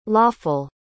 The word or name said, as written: loefel